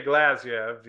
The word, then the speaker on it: Glazyev: This is Sergey Glazyev, the Okay.